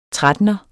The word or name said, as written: trettener